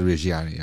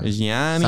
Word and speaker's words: Reggiani: They said Reggiani. Yeah. – Reggiani. – Sounded like cheese.